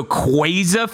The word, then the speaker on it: quaza: So Quaza feels awful. It feels like somebody saying quesadilla. I just hate it. – Yeah, it's rough. – So thanks for that. – It's pronounced by Napoleon Dynamite rules. – Like, it's apparently supposed to be based on Quasar.